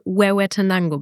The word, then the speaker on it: Huehuetenango: Und diesen Ort kann ich jetzt wirklich nicht aussprechen. Huehuetenango. – Wir würden die Region Huehuetenango bezeichnen.